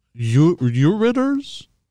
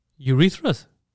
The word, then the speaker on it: ureters: Also includes two ureters? Two... I don't know how to say this word. – We're listening. – U-R-E-T-E-R-S. – Urethras? – No.